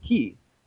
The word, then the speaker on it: ki: And so the word Ki, the root word turned into the word G that we use for geology and geography, but it was originally pronounced Ki.